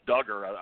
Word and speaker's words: Duggar: I believe it's Duggar is how they're pronouncing it.